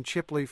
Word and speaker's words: Chipley: Chipley, Florida. Am I saying that correctly, Fred Peel? – That's exactly correct. – Fred Peel is a retired county sheriff in Chipley, Florida.